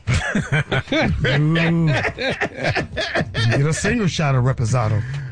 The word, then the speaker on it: Reposado: Give me a triple Raspado. Triple. – That word just sound expensive. – Yes. – Reposado, yeah. – I said, Reposado. That's how you pronounce it.